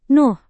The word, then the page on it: Nuh: Indonesian